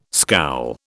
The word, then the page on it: scowl: British English